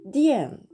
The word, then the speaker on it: 电: はい、で発音記号もですね「ディエン」という風になってなんとなく日本語のね「電に近いですよねだから覚えやすいと思います発音記号で書きますと「 DIAN」と書いて第 4 声上から下に急降下する音になりますはい、でこの次の文字この次の文字もですね第 4 声でコアと発音します。